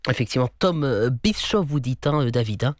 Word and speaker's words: Bischoff: C'est Bischoff. Oui, c'est Bischoff, on prononce « ch », il n'y a pas besoin de séparer, c'est okay. SCH et « ch » en allemand, Bischoff, oui.